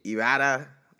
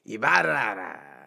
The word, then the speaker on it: Ibarra: It's actually Ibarra. Like, you're supposed to, I don't even say it right, but you're supposed to really roll your R's and like, Ibarra. A Mayan temple is supposed to shoot out from under you when you say it.